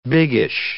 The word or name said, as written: biggish